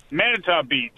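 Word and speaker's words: Manitou Beach: Monico Beach, is that how you pronounce it? Welcome. You're in Michigan. Monico Beach. Where's Monico Beach? – Manitow Beach.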